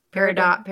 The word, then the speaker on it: Peridot: How do you say it? Peridot? – Peridot. It's my birthstone.